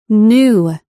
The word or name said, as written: new